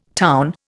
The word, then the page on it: town: Indian